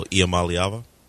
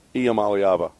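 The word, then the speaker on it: Iamaleava: I can't ever pronounce his name, Tennessee quarterback. – Nico Iamaliava. – Iamaliava, right.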